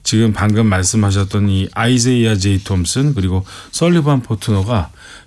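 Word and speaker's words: Isaiah: 그런데 이제 발음상 이게 표기법이 국립국원에서도 현재 아이제이아로 표기가 이제 확정이 되어 있고요. 그래서 네. 좀 약간 낯설지만 뭐 아이제이아 제이 톰슨 이렇게 부르게 됩니다.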